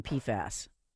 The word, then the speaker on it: PFAS: So that's the pronunciation, PFAS.